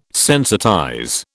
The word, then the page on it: sensitize: British English